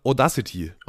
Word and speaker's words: Audacity: Wie spricht man das aus? Also ich habe das nie mal auch gesehen, weil ich, ich weiß nicht, wie man das ausspricht. Ich glaube Audacity.